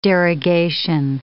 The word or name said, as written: derogation